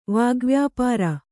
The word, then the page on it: ವಾಗ್ವ್ಯಾಪಾರ: vāgvyāpāra